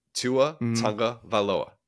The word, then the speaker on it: Tagovailoa: I'm like, you're adding an extra syllable in there. Tua Tagovailoa. That's it.